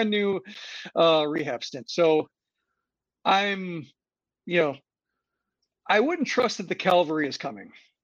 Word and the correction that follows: cavalry: Yeah. Cavalry. I mispronounced it.